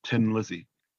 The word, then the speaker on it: Thin Lizzy: And there's a robot in that called Tin Lizzy. You know, the Irish pronunciation of thin is tin. You say Tin Lizzy.